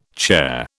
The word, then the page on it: chair: British English